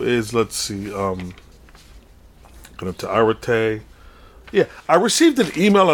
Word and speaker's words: arete: But the word is arete, and I'll spell it. A-R-E-T-E. Arete.